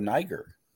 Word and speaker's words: Niger: So speaking of future wars and new conflicts developing, we have the country of Niger that was given an ultimatum to reinstate their president. – Is that how that's pronounced? Uh-oh. – Yes, it is. I know you've been getting it wrong.